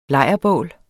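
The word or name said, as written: LEJRBÅL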